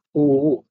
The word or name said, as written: uu